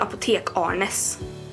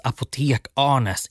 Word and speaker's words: Apotekarnes: Det är apotek Arnes. – På sjungande typ göteborgska avslöjar Klara hemligheten. Det uttalas apotek Arnes, inte apotekarnes eller apotekarnes eller ja, och så vidare.